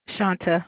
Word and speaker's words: Shanta: Shanta with a T.